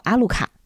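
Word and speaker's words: アルカ: 亚路加的日文发音呢是叫阿鲁卡，翻译过来就直译啊，中文的意思是有什么吗？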